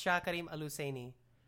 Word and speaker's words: Shah Karim al-Husayni: Ah, man, I even butchered that because I threw an extra H, but it's Prince Shah Karim al-Husayni.